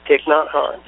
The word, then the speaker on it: Thich Nhat Hanh: Yes, Thich Nhat Hanh. – Mm-hmm, yeah, and Rigoberta Menchu Tum. – Yes. – And Eli Wiesel. – Yeah, Wiesel. – Wiesel, yeah. The funny thing about Thich Nhat Hanh is, first of all, most people don't realize how old he really is, and he's alive.